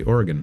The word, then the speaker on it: Oregon: Did I say it right? Oregon? – You did, actually. Oregon.